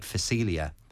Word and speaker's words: phacelia: Now, the great thing about phacelia, and it's spelled with a P, so the P is silent. – P-H-A-C-E-L-I-A.